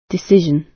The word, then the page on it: decision: British English